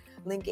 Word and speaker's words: Lnk: which I call Link, L-N-K is kind of spelled phonetically, Link Agency,